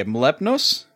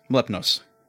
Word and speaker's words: Mlepnos: This is a timid man named, I even wrote this down, and I can't, okay, Mlepnos? Mlepnos. You see, it's spelled, M-L-E-P Clay, N-O-S. The clay is silent.